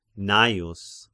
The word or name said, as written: Gnaeus